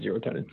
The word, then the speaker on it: zero tone: That's one that works better if you're reading it, because it's spelled almost exactly like serotonin, but it's zero tone.